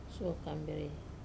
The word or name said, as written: suo kamberei